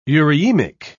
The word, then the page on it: eurhythmic: British English